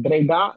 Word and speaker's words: Breda: is it Breda and is it Tilburg? – That's not bad. Breda and Tilburg, it's not bad.